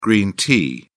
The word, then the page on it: green tea: British English